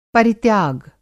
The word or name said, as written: परित्याग